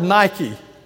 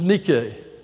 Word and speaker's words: Nike: We would say we pronounce that word Nike, and we would do so incorrectly. It is Nike.